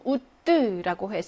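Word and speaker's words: août: 1번에는 우뜨라고 해서 요건 오, 으가 결합돼서 우 발음이 나는 거죠.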